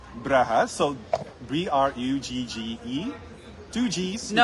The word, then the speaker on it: Brugge: English is Bruges, and then Flemish is Brugge.